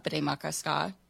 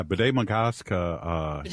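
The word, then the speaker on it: Bde Maka Ska: They started doing the sunrise ceremony at Bede Makaska in Minneapolis. – Yeah, Bede Makaska.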